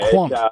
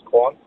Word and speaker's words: Quant: Is it K Want Quant Quant? How do you say his name? – Quant. Yeah. – Quant. – Probably the most obvious of it. It is Quant, and that was his first game for Canterbury, and he's a swashbuckling left-hander straight out of club cricket.